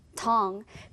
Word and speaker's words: tongue: 그런데 소리 나는 발음과 스펠링이 약간 다르거든요. 우리 같이 함께 발음해 볼게요. tongue, tongue. 그래서 이 단어의 스펠링을 보면 마치 소리가 텅구처럼 이렇게 보이지만 뒤에 소리가 나지 않아서 tongue, tongue으로 발음된다라는 거꼭 기억하면 좋겠습니다.